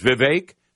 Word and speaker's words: Vivek: Vivek, that's the way you say his name, Nikki, Chris, Ron, or Donald Trump.